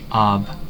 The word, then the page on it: by: /baɪ/